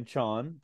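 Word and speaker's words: Chaon: I just saw on his Twitter that he tells us how to pronounce it. Dan Chon, to be included in Best Microfiction 2019.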